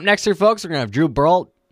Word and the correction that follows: Baldridge: Okay, I guess I can't say his name. It is Drew Baldridge coming in here with more music.